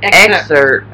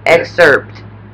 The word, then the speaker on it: excerpt: Expert. It's not expert. It's excerpt. – Excerpt. – Yeah. – Excerpt.